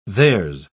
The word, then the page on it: there's: British English